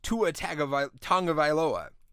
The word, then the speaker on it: Tua Tagovailoa: And the reason why is not just Tua Tagovailoa.